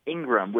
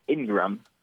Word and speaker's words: Ingram: It's Ingram, which was actually also mispronounced. – Ingraham? – Oh, really? – It's pronounced Ingram. I don't know why they would mispronounce that, but I-N-G-R-A-M.